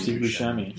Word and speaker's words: Buscemi: Steve Buscemi? – Steve Buscemi.